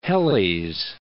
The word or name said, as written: Helles, Cape